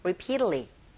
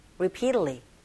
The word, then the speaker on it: repeatedly: 특히나 repeatedly, 이 부분은 이 부분에 강세 주시고, T 사운드가 모음사에 껴있네요. 네, 플랙 현상에 의해서 T 사운드가 R 소리에 가깝게 들려져서 여러분들이 들리는 소리, 또 여러분들이 발음하는 소리가 repeatedly, repeatedly 이렇게 하시면 되겠고, going through 하실 때 입모양 잡아주시면 되겠죠.